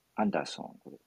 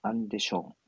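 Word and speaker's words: Andersson: この 2 人の女性ですね、えー、フィンランドのマリン首相、それからスウェーデンの、えー、アンダーソン、えー、正式な発音はアンデション首相というんでしょうかね。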